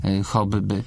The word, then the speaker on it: hobby: Wymowa hobby, która się czasami spotyka. – Dosyć często. – Nawet dosyć często, nie jest polecana przez żaden z polskich słowników. Nie jest zgodna z normą wzorcową. Możemy polecić tylko wymowę taką twardą, hobby.